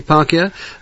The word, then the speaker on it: Hipparchia: Hipparchia. Is that how I pronounce it? – That's right. – That's yes. – Right, Hipparchia, a wealthy, virtuous, young maiden.